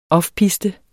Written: offpiste

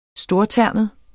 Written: STORTERNET

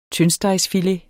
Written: tyndstegsfilet

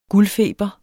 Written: guldfeber

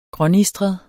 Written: grånistret

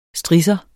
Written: STRISSERNE

